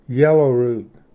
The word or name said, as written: ᏧᏩᏙᎭ